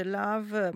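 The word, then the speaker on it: L'OV: Domani alle 18 allo spazio LAV di Lugano, si dice LAV ma si scrive l'apostrofo OV,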